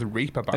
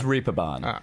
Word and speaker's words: Reeperbahn: is that how that's pronounced? That was Reeperbahn. – It's Reeperbahn.